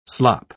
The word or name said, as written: slop